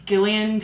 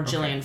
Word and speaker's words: Gillian: Yes, Gillian Flynn, or Gillian Flynn, but it's spelled with a G.